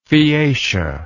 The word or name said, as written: phaeacia